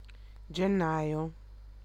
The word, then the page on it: janvier: [ɑ̃ ʒɑ̃.vje]; France